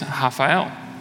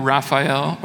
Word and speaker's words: Rafael: Um, originally from Brazil, my, uh, in Portuguese, my name is Rafael, Rafa for short. Now, I understand that there's Spanish version, Rafael, Rafa, there's an English version, Raphael or Rafa.